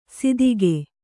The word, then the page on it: ಸಿದಿಗೆ: sidige